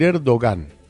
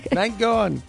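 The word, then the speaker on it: Erdoğan: Pero vio que no pronuncian la G. – No pronuncian nada. – Erdogan.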